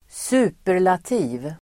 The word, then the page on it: superlativ: [²s'u:per_lati:v]